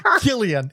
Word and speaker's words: Cillian: Yes, Killian. It's actually pronounced Killian. I know it really? How it's spelled.